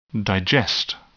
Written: digest

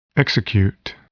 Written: execute

